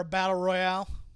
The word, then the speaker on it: Battle Royale: Chris, if you're going to have referees for a Battle Royale, or Battle Royal, depending on how you say it,